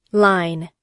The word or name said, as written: line